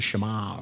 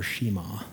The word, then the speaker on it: Shema: Another specifically and particularly Jewish reference that he uses is what's called the Shema, or Shema, some people pronounce it.